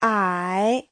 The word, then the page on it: 矮: ai3